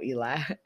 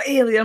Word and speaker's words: Ealy: So, you know, it's Michael Ealy. I pronounce him Michael Eli. That person who I was talking about, they used to always be like, his name is Michael Ealy. I'm like, it's Eli.